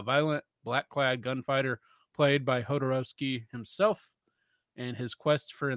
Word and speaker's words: Jodorowsky: Jodorowsky.